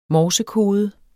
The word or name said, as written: morsekode